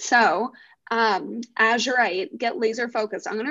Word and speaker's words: azurite: So this is called, I believe it's pronounced azurite, A Z U R I T E, and right under the name it says get laser focused, which wait, that's actually crazy.